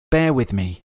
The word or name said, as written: bear with me